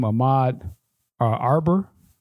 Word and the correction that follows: Arbery: or Arbery. Arbery. Arbery, uh, tw- what, uh, 25 years old.